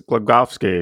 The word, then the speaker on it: Glogowski: That was, I guess it's Glogowski because, you know, you have to pronounce the W as a V, I think is how you do that.